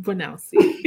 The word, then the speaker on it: Bwanausi: Oh, so much fancier when you say it, Bwanausi.